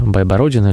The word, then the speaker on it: Байбородин: Мы долго выясняли, как правильно ставить ударение, Байбородина или Байбородин. Ну, вот наш гость убедительно утверждал, что Байбородин.